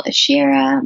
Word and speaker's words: Asherah: My website's Nicole Ashira. My last name is spelled A-S-H-E-R-A-H.